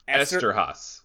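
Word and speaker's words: Eszterhas: Esterhaas.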